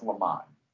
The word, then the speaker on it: Lemon: he has insisted that it's pronounced Lemon. So I just want you guys to know that it's Lemon.